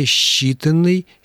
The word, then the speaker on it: считанный: А вот по поводу «считанной» и то же самое касается глаголов «счесть», и всевозможных других примеров, которые вы приводили, правила орфоэпии требуют, чтобы сочетания, передаваемые буквами «с» и «че», стоящими рядом друг с другом, читались как «ще». Правильное произношение «считанный», «счесть», «счастье» и так далее.